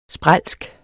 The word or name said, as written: sprælsk